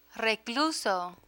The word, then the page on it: recluso: Spanish